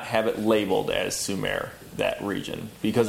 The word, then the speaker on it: Sumer: S-U-M-M-E-R. This is Sumer. – Okay. – It's spelled Sumer, though? – Yep, S-U-M-M-E-R, but it's pronounced Sumer.